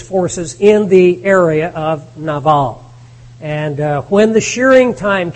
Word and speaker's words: Nabal: But in this particular story, we have a man by the name of Nabal, or Nabal as we usually pronounce it. Nabal would be more proper.